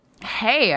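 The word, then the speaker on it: Haigh: Yeah, my number one movie of the year is Andrew Haig. Is it pronounced Haig or High? – Hey, I thought. Hey? – Hey. Haig? Yeah. – Okay, that's good to know. – Hey, just like hey, Andrew, like hey.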